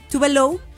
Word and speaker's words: Tove Lo: Bueno, sí, pero de verdad que me impresionó mucho la corporalidad que tiene Tube Low. – Se ve guapa, ¿eh? – Tove Lo, Dios mío, siempre me cuesta mucho la pronunciación correcta de su nombre ya, pero todos la conocemos como Tove Lo, y de verdad se mueve súper sensual.